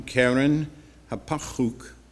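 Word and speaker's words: Keren-happuch: And the third one, Karen Hapachuk. Now, I know you think that's silly, but that's how it's going to be pronounced in Hebrew.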